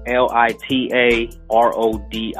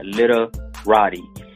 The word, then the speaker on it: Lita Rodi: A lot of people, they misspell it because it sounds the way it sounds, but it's actually Lita Rodi, Lita Rodi. It says Lita Rodi, And I have my website if you want to see the stuff that I do for schools as a motivational speaker.